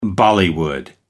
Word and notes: bollywood: American English

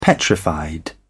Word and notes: petrified: British English

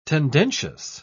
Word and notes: tendencious: British English

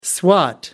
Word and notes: swat: American English

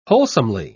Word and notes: wholesomely: British English

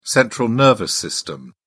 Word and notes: central nervous system: British English

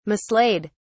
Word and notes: mislaid: British English

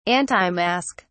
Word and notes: antimask: British English

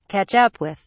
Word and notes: catch up with: British English

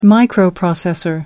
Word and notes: microprocessor: American English

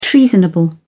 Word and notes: treasonable: British English

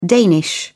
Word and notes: danish: British English

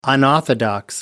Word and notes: unorthodox: American English

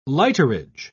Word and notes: lighterage: British English

